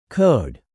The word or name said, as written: code